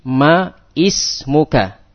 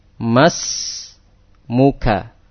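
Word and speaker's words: Mas muka (ما اسمك): Maka membacanya bukan seperti ma ismuka. Bukan seperti ini. Bukan seperti ini. Ma ismuka. Bukan seperti ini. Membacanya dengan gimana? Membacanya langsung disambung. Mas muka, mas muki, ya, mas muka, mas muki.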